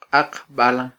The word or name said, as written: qaqbalan